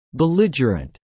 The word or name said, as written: belligerent